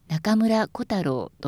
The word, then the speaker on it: 中村小太郎: その他、お客様の耳になまって聞こえてしまいそうなのは、尾上松也、中村小太郎、坂東彌十郎です。松也は尾上松也ではなく尾上松也と平らに言います。小太郎は小太郎ではなく中村小太郎と初めにアクセントがきます。